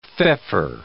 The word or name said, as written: feoffor